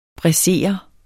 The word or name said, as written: braisere